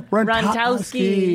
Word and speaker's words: Rontowski: Karen Rontowski.